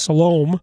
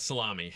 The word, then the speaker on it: Salome: When the Sabbath was passed, Mary Magdalene and Mary the mother of James and Salome. Is it Salome or Salome? – I just know it's not salami.